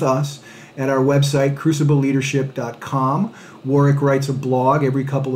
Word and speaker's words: Warwick: Uh, you can engage us on uh, LinkedIn at Warwick Fairfax, and that is Warwick with the silent W in the middle, W A R W I C K, at Warwick Fairfax.